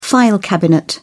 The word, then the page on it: file cabinet: British English